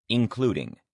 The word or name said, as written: including